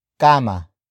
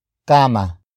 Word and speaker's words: kama: Sa ilalim ng kama. Sa ilalim ng kama.